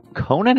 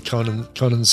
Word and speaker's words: Conan: The Atlantean sword is Conan's sword. Conan's sword. Conan hosts Conan's sword. Right. – It bums me out that those two have a different pronunciation of C-O-N-A-N. Listen, agree on one.